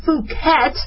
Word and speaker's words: Phuket: I can't make the short U because otherwise it sounds like blanket, but a Phuket B virus.